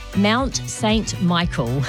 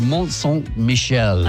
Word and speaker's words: Mont St. Michel: Well, I'm going to say it in a Kiwi accent. Mount St. Michael. – Okay, I'll go with Mont St. Michel.